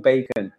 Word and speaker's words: BACN: It's called Bacon, B-A-C-N, huh. pronounced as in bacon, mm-hmm.